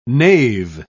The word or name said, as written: knave